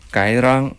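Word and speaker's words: Cái Răng: 它越南语发音叫 Gai Rang，这个其实应该是一个当地话，就是高棉语的一个翻译。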